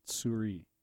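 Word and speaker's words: souris: I believe the S at the end is silent. Suri.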